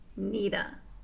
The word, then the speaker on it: Nita: Their name rhymes with Smita. – Nita.